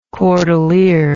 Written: cordelier

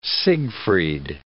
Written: Siegfried

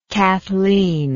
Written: cathleen

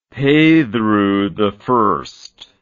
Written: Pedro I

